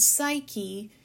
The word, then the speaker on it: Psyche: is Psyche, S-A-I-K-E, which is the pronunciation of the word Psyche, P-S-Y-C-H-E, which means the breath of soul, and they are beginning a new archetypal journey.